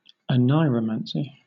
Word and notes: oneiromancy: British English

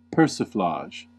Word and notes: persiflage: American English

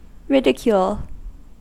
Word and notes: ridicule: American English

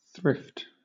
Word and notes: thrift: British English

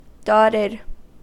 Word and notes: dotted: American English

